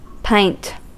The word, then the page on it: pint: /paɪnt/; [paɪnt]